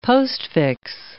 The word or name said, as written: postfix